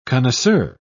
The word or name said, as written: connoisseur